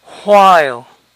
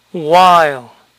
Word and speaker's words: while: La doble U con H inicial formalmente suele pronunciarse who, who, pero en conversación se pronuncia u, u. Por ejemplo, mientras formalmente sería while, while. En conversación es más común escuchar while, while.